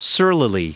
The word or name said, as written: surlily